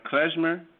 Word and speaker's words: klezmer: And I think by researching, please help me pronounce this right, klezmer, is that how you pronounce it? – Yeah, that's right.